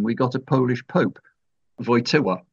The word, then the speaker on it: Wojtyła: And we'd been recommended Wałęsa and Wojtyła. But the L in both those names has a bar through it, which means that it's actually pronounced like an English W rather than an English L.